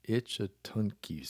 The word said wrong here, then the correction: Ichetucknee: Springs. Good grief. Let me try this one more time. Ichetucknee. I did it.